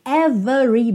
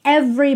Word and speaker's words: every: Então, essa palavra every, por norma, por padrão, não tem o E do meio pronunciado. Lembra lá do The Police, outra aula que está aqui na série Aprenda Inglês com Música? Every breath you take. Every, every. Você pensa como se fosse VR, every, every. Mas às vezes você ouve every, every e não é à toa, muito menos é um erro.